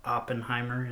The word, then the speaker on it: Oppenheimer: And it compared him to a man named Robert, how do you say his last name? Oppenheimer? – Oppenheimer is how.